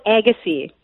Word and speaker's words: Agassiz: How do you pronounce that? – It's called Agassiz.